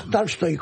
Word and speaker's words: Tolstoy: Tolstoy, con A, una.